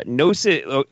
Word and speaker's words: Gnosis: Is it spelled, does it have a silent K at the beginning of it? – No, it's G-N-O-S-I-S. Gnosis.